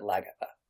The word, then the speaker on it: Lagertha: So anyway, on the show Vikings, there is a particular warrior woman named Lavgirtha, or they pronounce it Lagatha on there. Probably because it's really hard to say Lavgirtha.